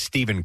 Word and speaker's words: Steph: It says Stephen Curry, but you wanted me to say Steph. – Yeah, because it's not pronounced Stephen. It's like Stephen.